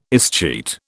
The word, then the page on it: escheat: British English